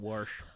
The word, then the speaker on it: wash: So it was sort of a wash, if you get my meaning. – I pronounce it warsh, but yeah.